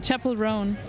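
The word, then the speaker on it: Chappell Roan: Rome? Chapelle Rouen. – Chapelle Rouen. – Okay, ich habe es komplett falsch ausgesprochen und werde hier gleich korrigiert live.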